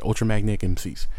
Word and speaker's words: Ultramagnetic MCs: which is the Ultramagnetic MCs. Why did I say it like that? Ultramagnetic MCs.